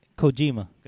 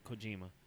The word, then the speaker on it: Kojima: Pronounce his name. – Kojima. – There you go, Kojima. – Kojima and Nagata. You guys can have a match. Loser. – Kojima sounds like Neutrogena.